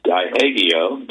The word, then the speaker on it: Diageo: Three companies, Pepsi, Rockstar Energy, and I guess it's pronounced Diageo, D-I-A-G-E-O, have withdrawn their sponsorship of the festival.